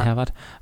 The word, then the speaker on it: Herwart: Herwart, wird er deutsch ausgesprochen? Ja. Herwart heißt der Sturm.